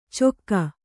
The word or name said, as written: ಚೊಕ್ಕ